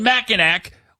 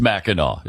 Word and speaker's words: Mackinac: Why do they spell it Mackinac when it's Mackinac? It doesn't make any sense.